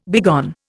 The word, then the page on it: begone: Indian